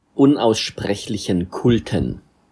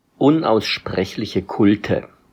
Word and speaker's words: Unaussprechlichen Kulten: Anyway, unaussprechlichen Kulten. Cringe. – Very German reaction. – Yeah, he cringed at the end. Let's hear the correct pronunciation of the German title for Nameless Cults. – Unaussprechliche Kulte.